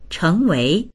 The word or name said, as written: cheng2wei2